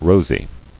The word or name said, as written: Rosily